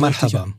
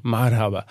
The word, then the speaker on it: Marhaba: Äh, Merhaba, äh, wie, diese, wie spricht man so Marhaba. Marhaba.